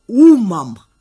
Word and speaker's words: умом: что до встречи с нами он жил только умом с ударением на «у», а теперь зажил сердцем.